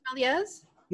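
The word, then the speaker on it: Méliès: Oh, George Melies? – Yeah, thank you.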